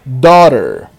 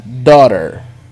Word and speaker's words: daughter: Como se fosse D-O-R-E-R. Imagina que você está escrevendo essa palavra. D-O-R-E-R. Esse é o som que você vai utilizar para falar filha. Daughter. Com som de O bem aberto.